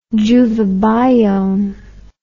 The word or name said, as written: juvabione